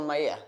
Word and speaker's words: Maillet: Quentin Maillet uttalar ja. det rätt? – Mm. Ja.